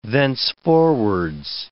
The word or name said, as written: thenceforwards